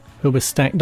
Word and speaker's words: Hoobastank: Hoobastank. Don't put the emphasis on the stank. Just call it Hoobastank.